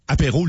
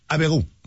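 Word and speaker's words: Abbey Road: Ça a l'air que ça se prononce pas apéro, le studio. Ça a l'air que ça serait pas ça. Non, il y a une autre. J'ai dit apéro. – Abéro. Ça, c'est la bonne prononciation.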